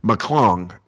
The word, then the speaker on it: McClung: Is it McLaughlin? How do you say his last name? – McClung. – Yes, McClung.